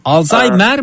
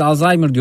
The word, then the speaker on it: Alzheimer: Alzheimer mi hocam? Biz Alzheimer diyoruz ama hata mı yapıyoruz? – Aslında Alzheimer İngilizce telaffuzu. Türkçe telaffuzu Alzheimer.